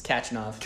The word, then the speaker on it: Khachanov: Is that how I say it right? – It's Khachanov.